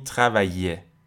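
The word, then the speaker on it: travailler: تو فرانسه میگن که اکثر مواقعی که شما ترکیب ای ال ال رو دارین تلفظ شبیه یه تشدید دار میشه مثلا اینجا میگین تخویه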